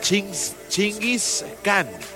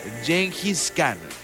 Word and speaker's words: Genghis Khan: Y la banda alemana, no sé cómo se pronuncie, pero algo así es Chingis Khan. Se pronuncia Genghis Khan.